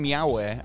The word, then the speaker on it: Yowie: Yowie, as well, if you want to look them up, it's spelt the same as Bowie, but with a Y.